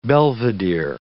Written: belvedere

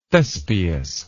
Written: thespius

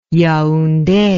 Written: yaounde

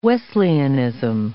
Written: Wesleyanism

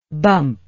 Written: bumpingly

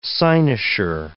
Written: cynosure

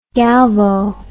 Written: galvo

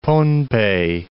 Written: Pohnpei

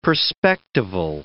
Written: perspectival

